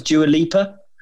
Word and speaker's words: Dua Lipa: Harper's quite popular because of the Beckhams, and Dua, is that how you pronounce Dua Lipa? – Yeah, that's gone from.